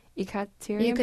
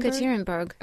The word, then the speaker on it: Yekaterinburg: How do you say it again? I think I pronounced it wrong because I heard you pronounce it a different way. Yekaterinburg. – Yekaterinburg. Okay, you, you, were exactly right.